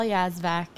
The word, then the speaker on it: Yazvac: You could find me first and last name, Michelle Yazvac.